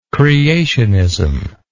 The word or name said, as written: creationism